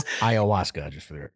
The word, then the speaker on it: ayahuasca: Ryan McCarthy, it says ayahuasca.